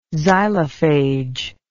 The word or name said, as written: xylophage